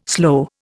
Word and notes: slow: Indian

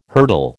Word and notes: hurtle: American English

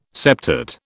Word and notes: septate: British English

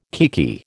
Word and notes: kiekie: American English

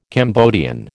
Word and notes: Cambodian: American English

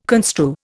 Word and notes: construe: Indian

